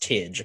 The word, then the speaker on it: Tig: No, Adekunle, and it's spelled Tig, but it's pronounced Tidge, apparently.